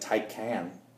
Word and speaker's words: Taycan: That is like Taycan. – Taycan? I think it is Taycan.